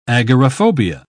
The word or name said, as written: agoraphobia